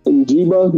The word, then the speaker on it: Njigba: Njigba, yeah, him. Njigba, I do like him, but I don't know.